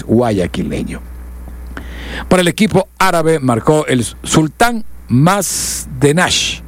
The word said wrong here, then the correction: Mandash: Mandash. Lo que pasa es que se escribe un poco raro, pero es Mandash lo que tiene razón, Marcelo.